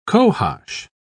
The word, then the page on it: cohosh: British English